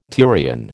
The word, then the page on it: turion: American English